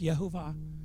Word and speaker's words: Yehovah: A lot of people say it Yahweh. It's pronounced Yehovah.